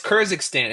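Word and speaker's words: Kjerstad: How do you say his last name? – What is Kernekstan? – Who's that? – Say it. He's a top prospect. It's like KJ. I don't know how you say his last name. – It's Kurzistan.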